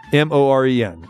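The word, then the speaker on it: Ibutamoren: I know, but it looks like ibuprofen, but it's spelled because it's an I and a but, and it's ta, and then it's morin. There you go. Yeah, look that up. Ibutamorin.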